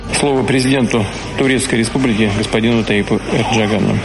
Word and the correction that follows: Ердоган: Реджепу Таїпу Ердогану.